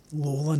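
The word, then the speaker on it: Lalor: As you said, they used to do the Eureka, the Eureka pilgrimage, if you will, because of course Peter Lawler, and that is how you pronounce the name Lawler, not Laylaw, not like the suburb.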